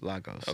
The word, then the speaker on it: Lagos: Is that how you pronounce it? Lagos? – Lagos. – Okay. I wasn't sure how to pronounce it.